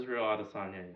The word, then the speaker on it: Adesanya: Robert Whittaker versus Israel Adesanya? Is that how you pronounce his name? – Israel Adesanya, yes.